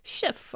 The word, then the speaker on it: Schiff: How would you pronounce that last name? – Schiff, okay.